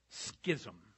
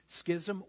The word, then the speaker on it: schism: I actually looked this up because I wasn't exactly sure how it should be pronounced, and I found that it can be schism or schism. You can pronounce it either way.